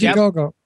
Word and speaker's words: Indiegogo: Parlaying these into wonderful locations for this really small movie, and it's pure independent film, and Indiegogo, or however it's pronounced, Indiegogo, I guess. – Indiegogo.